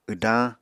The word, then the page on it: wdañ: Apatani